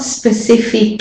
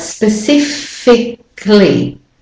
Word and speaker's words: specifically: The AL part is omitted. So it's not specifically, but specifically. Fickly. You go from the K sound to the O sound straight away. Specifically. Specifically.